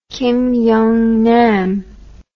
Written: Kim Young Nam

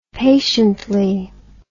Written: patiently

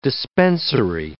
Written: dispensary

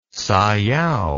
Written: sayao

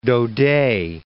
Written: Daudet

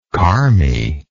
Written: carme

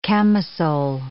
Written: camisole

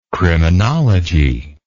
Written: criminologist

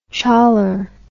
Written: choller

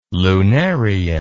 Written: lunarian

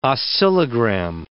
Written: oscillogram

